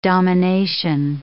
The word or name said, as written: domination